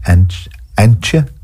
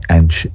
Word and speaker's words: Antje: Yeah, Antje. A-N-T-J-E.